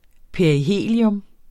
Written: perihelium

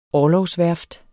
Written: orlogsværft

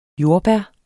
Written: jordbær

